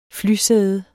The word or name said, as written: flysæde